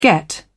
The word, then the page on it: get off: British English